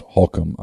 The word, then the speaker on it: Holcomb: That's spelled Holcomb, but it's pronounced Holcomb, I'm told. H-O-L-C-O-M-B.